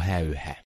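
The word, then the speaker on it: Häyhä: So where I say Simo Heha, Finnish people say Simo Heha.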